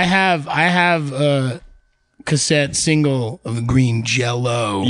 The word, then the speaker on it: Green Jelly: I think I got Green Jelly, or it's pronounced Green Jello. – Did you get it as Jello or Jelly? – It had a Y, but I think the thing with them is you always pronounce it Green Jello.